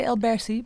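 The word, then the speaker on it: Elberse: The pronunciation, Elbersi? – That's pretty good. I say Elbersi, but Elbersi is pretty good.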